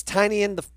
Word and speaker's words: Tynion: James Tynion the Fourth.